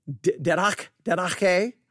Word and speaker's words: didache: Again, didache is how you say it. I would just say didache, but didache are also translated teacheth and learning.